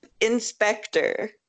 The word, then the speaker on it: Inspectr: Inspector. – Okay. – It's a combination of, it's like a pun, so it's Inspector as in, like, detective, but it's spelled I-N-S-P-E-C-T-R. So, in and Spectre.